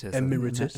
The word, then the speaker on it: Emeritus: Yeah, so this is Chairman Emeritus, is that right? Is that how you say it? – Emeritus.